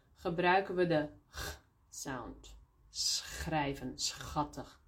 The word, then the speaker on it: schattig: Maar soms hebben we CH, zo CH, combinatie, die we ook uitspreken als een G. Bijvoorbeeld, for example, in school. S-C-H, school. Of schattig, cute. S-C-H, schattig.